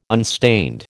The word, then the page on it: unstained: American English